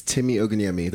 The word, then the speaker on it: Timi Ogunyemi: Do you want to say your name properly? – It's Timi Ogunyemi.